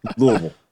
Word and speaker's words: Louisville: Yeah, it's clearly Louisville. – Louisville.